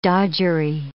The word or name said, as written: dodgery